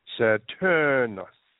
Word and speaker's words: Saturnus: Saturnius, Latin pronunciation, Saturnus.